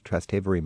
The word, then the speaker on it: Trastevere: And then you get the old Trastevere magic. – Exactly. – How do you spell that? – Trastevere. It means across the Tiber River. – Trastevere.